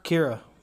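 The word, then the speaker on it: Qi'ra: So I guess to kind of unpack it, you know, we get Han and, I can never pronounce her name right, Kira? – Kira. Yep.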